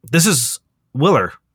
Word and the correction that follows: Willow: Or, no, sorry, immediately corrected. Pronounced as Willow.